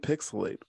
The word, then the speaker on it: PixelHate: This has been Pixelate, where the H is silent, but the hate is real.